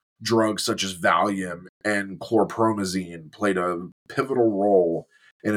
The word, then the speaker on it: chlorpromazine: Valium and chlorazepam? – Chlorpromazine. – Oh, yeah. Chlorpromazine.